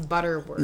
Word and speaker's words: Butterworth: What about Mrs. Butterworth? – Okay, hold on. I just threw her away the other day. – Which one? – Mrs. Buttersworth. Because that was... – It's not Buttersworth, it's Butterworth.